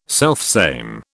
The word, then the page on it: selfsame: British English